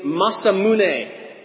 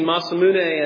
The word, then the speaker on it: Masamune: As far as what is noted, though, as the sharpest sword in history, comes by way of a Japanese swordsmith named Masamune. And I don't think I'm pronouncing that right. But you know what? Oh, is it really? I'm pronouncing it? Yes.